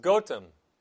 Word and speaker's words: Gotham: The village's name derives from Old English gat or goat and ham or home, literally homestead where goats are kept, and is pronounced Gotham, a similar name which has not undergone a pronunciation shift.